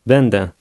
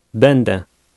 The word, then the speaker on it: Będę: Będę. – Did you hear the two different vowel sounds? This vowel has one sound when it appears in a word, but a different sound when appearing at the end.